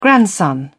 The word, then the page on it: grandson: British English